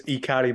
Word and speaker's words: Ikari: Right now, the most recent album by Gendo Ikari would be, this is the one I forgot to ask you how to pronounce, Roku Bungie. Did I get that right? – Yeah, that's right. It's Ikari, by the way.